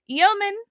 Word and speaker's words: yeoman: So the yeoman, did I say it right that time? – You did. – Okay, good. – You pronounced yeoman perfectly.